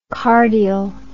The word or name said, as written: cardial